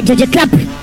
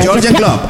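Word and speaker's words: Jürgen Klopp: Se dice Jurgen Klopp. – Se dice esa. – Jurgen Klopp. Ah, ok. ¿Cómo es? Ah, no, pero esa pronunciación no es británica, sino alemana.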